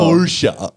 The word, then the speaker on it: Porsche: Porsche. – Why Porsche. Is it Porsche? – Because it's a German car company. It has an E at the end, and the E's at the end of words in German.